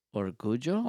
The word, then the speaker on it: orgullo: O-R-J, like, as in grief. – Yeah, O-R-G? – G. O-R-G-U-L-L-O. – Orgullo? – Orgullo. – And that is pride? – Yes. – Okay. So, I am very proud. – Yo soy muy orgulloso.